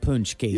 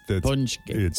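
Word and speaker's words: pączki: So if people are wondering what a punchki is, It also doesn't have a U in it, right? – But it doesn't. No. It isn't spelled like Punchki.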